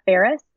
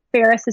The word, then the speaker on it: Ferris: It's my first name and my last name, Paula Ferris. And Ferris is spelled just like the city of Paris, with an F, like Frank.